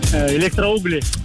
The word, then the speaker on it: Электроугли: Электроугли. – Вот, электроугли и электроугли. Да. Пытались мы разобраться, если что, ударение на «у» — электроугли, а жителей города электроугли как зовутся, стало быть? – По мне так электроуглята, я не знаю.